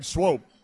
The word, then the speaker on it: Swope: All right, you say his name like nope, and nope is what he never gave a receiver because nobody ever caught passes against Craig Swope.